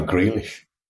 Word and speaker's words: Grealish: He would want me to ask as well, we have some disagreement about the pronunciation of Jack G's name. Grealish, Greylish, Grey Irish. You're a native of that neck of the woods. How do you say it? – I pronounce it twat. I'm joking. No, I'll go Grealish.